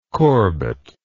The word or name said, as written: korbut